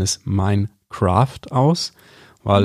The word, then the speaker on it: Minecraft: Ähm, ja, Minecraft meines Erachtens ist die richtige Aussprache.